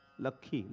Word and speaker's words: लक्ष्मी: लक्खी लक्खी लक्खी, मतलब क्या लक्ष्मी को वो कहेंगे लक्खी लक्खी, लिखा तो होता है लक्ष्मी,